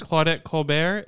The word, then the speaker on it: Claudette Colbert: Best Actress, Claudette Colbert. Is that how you pronounce her name, right? – Yeah.